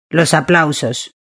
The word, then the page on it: los aplausos: Spain